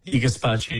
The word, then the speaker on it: gazpacho: Gazpacho, I have heard of it before, but is that how you say it? – Gazpacho, yes, one of my favorites.